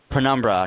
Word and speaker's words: Penumbra: Penumbra.